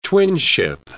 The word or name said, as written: twinship